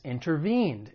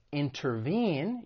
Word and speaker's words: intervene: The stress is on that third syllable, intervene. One thing I want to point out when it comes to the American accent, because I'm from the United States, is when the T follows an N sound, especially in unstressed syllables, it may elide and people may drop it. So instead of intervene, you may hear someone say intervene.